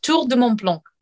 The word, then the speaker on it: Tour de Mont Blanc: Tour de Mont Blanc.